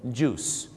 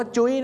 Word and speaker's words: juice: juice ไม่ได้ออกเสียงว่าจุ้ยนะฮะ juice นะครับผมท้องเสีย juice เลยนะครับผมน้ำผลไม้ไม่ใช่จุ้ยนะฮะต้องออกเสียงว่า juice